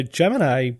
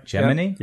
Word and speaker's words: Gemini: Did you know that some of the astronauts pronounced that word Gemini? – Gemini. Yep. – It's like hegemony. I thought hegemony.